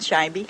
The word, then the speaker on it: Scheibe: Irene Scheibe. – And how do you spell that? – S-C-H-E-I-B-E.